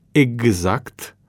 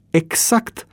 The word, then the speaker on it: exact: Și pentru că în limba română, cum scrim, așa și citim, se spunea prin anii 50, ar fi trebuit să pronunț exact cu CS și nu cu GZ.